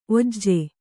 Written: ಓಝ

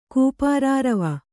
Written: ಕೂಪಾರಾರವ

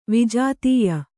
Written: ವಿಜಾತೀಯ

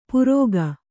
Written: ಪುರೋಗ

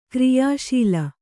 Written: ಕಾರ್ಯಶಾಲೆ

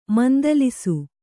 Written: ಮಂದಲಿಸು

